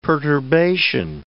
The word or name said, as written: perturbation